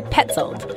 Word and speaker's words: Paetzold: So Petzold, it's spelled P-A-E-T-Z-O-L-D.